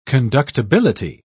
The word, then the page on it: conductibility: British English